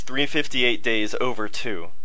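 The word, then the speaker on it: 358/2 Days: It's Kingdom Hearts 358 Days Over 2. That's the proper way to say it.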